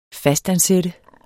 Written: fastansat

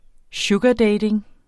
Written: sugardating

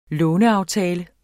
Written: låneaftale

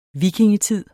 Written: vikingetid

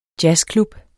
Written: jazzklub

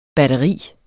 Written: batteri

